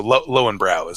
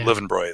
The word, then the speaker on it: Löwenbräu: Löwenbräu is what we say in the U.S., but Löwenbräu is how it's pronounced over there.